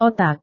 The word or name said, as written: otak